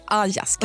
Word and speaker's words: Alaska: Tidigare i Sverige uttalades Alaska som Ajaska.